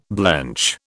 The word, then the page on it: blench: British English